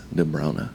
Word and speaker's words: De Bruyne: We did get an announcer during the Euros who clarified that the Flemish preferred pronunciation of De Bruyne is De Bruyne.